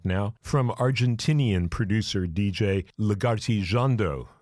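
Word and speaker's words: Lagartijeando: creating quite a lush electronic sound from Lagarti Jando. I will spell that full name for you because it's a weird one. It's L-A-G-A-R-T-I, Lagarti, and it's one word, Jando, J-E-A-N-D-O, Lagarti Jando.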